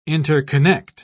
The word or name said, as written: interconnect